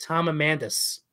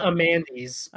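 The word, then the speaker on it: Amundson: Does he pronounce his name Amandes? – You know, no, like I noted while listening that he pronounces it Tom Amandus. – Amandus. Interesting. – Not Amandes, but Tom Amandus. – I've been saying it wrong this whole time.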